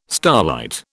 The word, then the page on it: starlight: British English